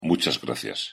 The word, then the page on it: muchas gracias: Spanish